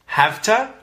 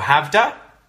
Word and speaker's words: have to: When we say this with quick pronunciation, quick connected speech, it turns into havta or havda and hasta or hasda. So the T can become a bit of a D sound.